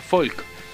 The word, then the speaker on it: Volk: Volk se pronuncia Folk. – Folk. – La B corta en alemán se pronuncia como una F.